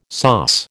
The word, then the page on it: sauce: American English